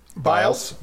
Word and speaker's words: Biles: Biles with a long I, sorry, Biles.